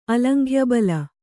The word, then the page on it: ಅಲಂಘ್ಯಬಲ: kannada